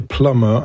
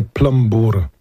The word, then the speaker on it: plumber: Mówi się plumber, a nie plumbur. Plumber. Nie wymawia się B.